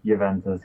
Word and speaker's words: Juventus: Juventus, yeah.